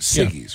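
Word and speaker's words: Siggi's: So, Siggy's. Am I saying that right? – Yogurt? – Yeah. Yogurt. Siggy's, yeah.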